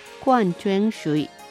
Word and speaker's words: 矿泉水: ミネラルウォーターは矿泉水、矿泉水と言います。漢字は鉱山の鉱という字ですね。石を書いて広いという字の簡体字を書きます。そして泉の水と書いて矿泉水と発音します。